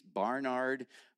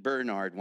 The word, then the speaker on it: Barnard: Bert and Charlotte Croft lived in East Barnard, Vermont. And you better pronounce it right. I pronounced it Bernard once, and I got told about it because Vermonters are jealous of their towns.